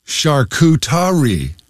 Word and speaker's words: charcuterie: And another word that trips me up all the time is, I hope I can pronounce this here with you, charcuterie. It's actually charcuterie.